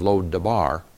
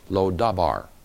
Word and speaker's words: Lo-Debar: However, in mentioning Israel's recovery of the retrieved city of Lo-Debar, Amos intentionally mispronounced it as Lo-Dabar, meaning nothing.